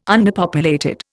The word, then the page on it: underpopulated: Indian